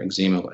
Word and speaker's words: eczema: I was diagnosed with adult eczema, or eczema as we pronounce it here in Canada.